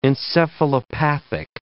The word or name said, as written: encephalopathic